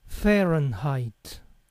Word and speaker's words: Fahrenheit: En los Estados Unidos no se usa centígrados para medir la temperatura, sino se usa la escala de Fahrenheit, que se pronuncia Fahrenheit.